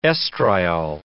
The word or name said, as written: estriol